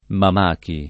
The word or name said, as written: Mamachi